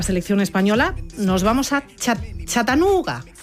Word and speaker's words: Chattanooga: Yo no sé decirlo. Vamos a intentar que nos ayude... Eso que pone siempre en Google de pronuncia, no sé qué. Vamos a escuchar cómo lo dice. – Chattanooga. – A ver, Chattanooga. ¡Uy, qué bien me ha quedado!